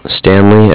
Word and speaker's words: Stanley: Stanley, S-T-A-N-L-E-Y. – Stanley, that's the, okay. – And then I'm calling with Cardiff. That's C-A-R-D-I-F-F.